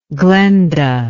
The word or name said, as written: glenda